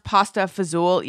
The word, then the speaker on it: pasta e fagioli: I believe it is pronounced, I read that it's pronounced pasta fagioli, even though it is spelled pasta e fagioli.